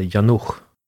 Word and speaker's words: Janouch: Jag har fått för mig, tror att jag lärt mig, att CH på tjeckiska uttalas som ett tyskt ach. Man borde uttala henne Janouch. Men jag tror inte folk brukar säga så. – Nej, det brukar vara mer sch. Men det ska uttalas lite hårdare, lite mer tyskt.